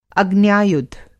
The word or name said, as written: अग्न्यायुध